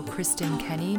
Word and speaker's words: Kristin Kenney: On Facebook, you can follow Kristin Kenney.